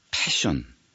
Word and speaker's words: passion: 아, 오늘 정열에 대한 이야기 시작했습니다만, 자, 근데 이제 로드 스튜어트를 보고 지금 제가 부러워하고 있는 이게 이제 요점이 아니라 이 지점에서는요, 패션, P-A-S-S-I-O-N 이게 우리말 발음에서는 P하고 F를 잘 구별을 안 하니까 저희가 그 요즘 케이블 TV에서 패션쇼 중계 주는 채널 있지 않냐 이렇게 얘기해야지.